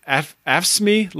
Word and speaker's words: AFSCME: Versus the, what is it, AFSCME Local 2206? Did I say that right? – You got it.